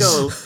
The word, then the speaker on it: Chico: Chico is actually pronounced Chico because he was, in the slang of the period, a chicken chaser.